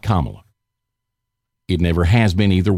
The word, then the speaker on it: Kamala: Or it's supposed to be pronounced like a comma, Kamala Harris.